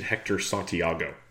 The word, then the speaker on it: Santiago: The Tigers are a very interesting play today because they're facing Hector Santiago. Yes, I did not mispronounce that.